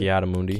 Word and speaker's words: Ki-Adi-Mundi: Ki-Adi-Mundi.